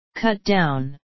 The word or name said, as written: cut down